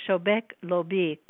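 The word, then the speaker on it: Shobeik Lobeik: I think that you say Shobek Lobik for the group. It's spelled like S-H-O-B-E-I-K, capital L-O-B-E-I-K.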